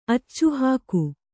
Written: ಅಚ್ಚುಹಾಕು

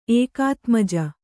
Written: ಏಕಾತ್ಮಜ

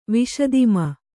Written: ವಿಶದಿಮ

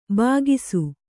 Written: ಭಾಗಸ

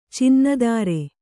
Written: ಚಿನ್ನಧಾರೆ

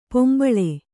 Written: ಪಿಂಬಳಿ

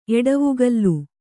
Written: ಎಡವೆಗಲ್

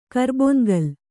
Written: ಕರ್ಬೊನ್ಗಲ್

